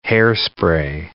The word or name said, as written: hair spray